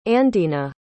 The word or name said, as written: andena